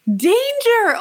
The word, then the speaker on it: Danger: Danger.